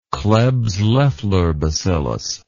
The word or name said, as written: klebs loffler bacillus